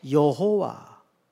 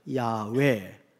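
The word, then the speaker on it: 야훼: 그리고 두 번째는 여호와 또는 원문에 가깝게 발음을 하자면 야외, 같은 표현이에요.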